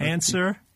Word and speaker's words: answer: Silent P, right? Or answer. – W. – W and Neil.